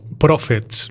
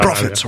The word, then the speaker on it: Prophets: Bueno, el supergrupo formado por miembros de Rage Against the Machine, Cypress Hill y Public Enemy, Profesor Profets, los profes de la rabia, les voy a llamar así.